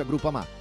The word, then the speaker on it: Groupama: Groupama, si pronuncia Groupama, di Paolo Felicetti.